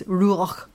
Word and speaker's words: Ruach: And I want to give you the Hebrew words, Ruach Adonai, or R-U-A-C-H. R-U-A-C-H, Ruach Adonai, A-D-O-N-A-I.